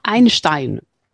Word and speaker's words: Einstein: Einstein.